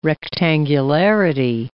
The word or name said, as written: rectangularity